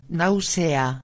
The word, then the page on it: nausea: espanhol